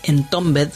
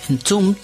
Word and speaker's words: Entombed: Otra banda con E importante, las que nombré anteriormente, de todo el pelotón de bandas con E de heavy metal, es Entombed, así es como la conocemos en Argentina. Mal pronunciado, por supuesto, es Entombed.